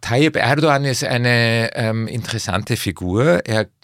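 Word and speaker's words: Erdoğan: Beginnen wir mal mit der Person Erdogan. Kannst du mal ein bisschen schildern? – Das G spricht man, weil es ein Hackerl drauf ist, wie ein J aus. Erdogan.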